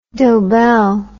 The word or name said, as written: dobell